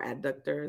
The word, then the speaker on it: adductor: He was asked about like the illness he had after getting like healing from the abductor, because, or adductor, is that how you say it?